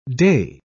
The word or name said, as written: dey